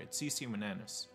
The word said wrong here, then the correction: Winans: I think. Winan, Winans, Winans, Winans, that's it, CeCe Winans.